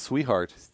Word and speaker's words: Swihart: And I've heard it pronounced Sweetheart, which would be cool if it's actually pronounced that way.